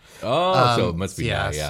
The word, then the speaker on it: Nighy: So I guess it is Nighy. – Yeah. – But it has a Y at the end, which is why I wanted to say it like that. It's N-I-G-H-Y. – N-I-G-H-Y. N-I-E.